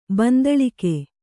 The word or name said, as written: ಬಂದಳಿಕೆ